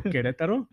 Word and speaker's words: Querétaro: What, Querétaro? That's how you say it.